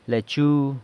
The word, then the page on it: lechu: sanlucasquiavini